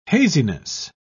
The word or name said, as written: haziness